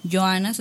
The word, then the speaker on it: Johanna: Pues bueno, Joana se escribe J-O-H-A-N-N-A.